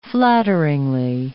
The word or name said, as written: flatteringly